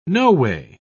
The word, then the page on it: noway: British English